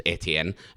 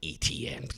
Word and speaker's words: Etienne: We've lost Travis Etienne, and it will be pronounced Etienne as long as I'm in charge of this podcast. Etienne. There's Kyle Pitts.